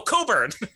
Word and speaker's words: Cockburn: It's spelled C-O-C-K-B-U-R-N. – But it's actually a very common name here in Canada. So every time you're like Cockburn, I'm like, what? What? No, Coburn.